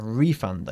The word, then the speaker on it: refund: We accentuate the first syllable. A refund. Again. – A refund. – Refund, not fond.